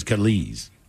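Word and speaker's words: Kelis: See, I went to the phonetic thing to pronounce it. It says Kellis.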